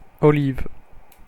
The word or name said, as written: olive